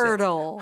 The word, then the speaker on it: hurdle: 근데 발음이 완전 허들이 아니라 들려주세요. – Hurdle.